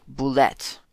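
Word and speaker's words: Bulette: Arctic Boulette. Although, actually, I read in Dragon Magazine, an early version of, early edition of Dragon Magazine, that Boulette was actually officially pronounced Boulet.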